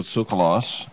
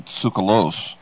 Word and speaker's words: Tsoukalos: All right, we are talking with Giorgio Tsoukalos, actually Tsoukalos, I said it wrong.